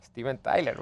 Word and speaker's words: Stephen: Stephen o Steven. – Stephen Tyler, bueno. – Stephen Tyler. ¿Está bien así? – Sí, así está bien.